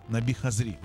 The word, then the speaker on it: Наби Хазри: И, кстати, Тофик Кулиев, он одновременно и написал музыку для песни, которую будем слышать, а автор текста Наби Хазри, или Хазри, Наби Хазри, вот так правильно говорить.